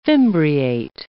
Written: fimbriate

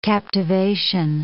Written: captivation

